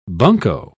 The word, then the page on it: bunco: British English